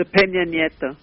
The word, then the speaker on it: Peña Nieto: There seems to be enormous enthusiasm, and there's got to be a change, a demonstrative change, from the Peñol. I keep doing that. I am not pronouncing it correctly. Help me. – The Peña Nieto.